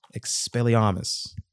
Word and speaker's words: Expelliarmus: Man, Warriors, we got to wait a little bit longer to do our Expelliarmus.